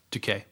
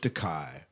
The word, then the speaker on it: Takei: Our favorite Star Trek veteran George Takei, Takei, Takei, Decay. Takei. There we go. R- rhymes with William Shatner's toupee. Yeah, toupee, mm-hmm. toupee.